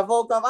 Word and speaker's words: Volkov: Oh, Alexander Volkov. – Yeah, Volkov.